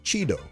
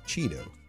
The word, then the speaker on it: chido: Today's word is Cheeto. Cheeto. – Oh, like a Cheeto? Chips? – No, or Cheetah. Or that too. Yeah, it's spelled C-H-I-D-O.